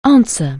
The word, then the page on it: answer: anglais